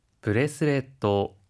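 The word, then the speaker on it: ブレスレット: 브레스렛도, 브레스렛도, 브레스렛도, 팔찌인데요. 혹시 발음 팁이 있을까요? – 하이, 넥크레스, 브레스렛도 라고 할 때는 중간에 초금이 들어가는데요. 한 박자 쉬었다가 발음하는 점 주의해 주세요.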